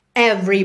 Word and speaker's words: every: Every breath I'm deeper into you. Every breath. Atenção aí que o E do meio da palavra every não é pronunciado, então every breath I'm deeper into you.